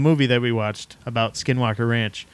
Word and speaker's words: Skinwalker Ranch: Skinwalker Ranch.